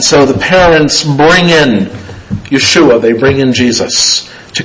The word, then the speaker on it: Yeshua: was the Hebrew name Yeshua. We would pronounce it today as Joshua, but it's the same meaning as Jesus.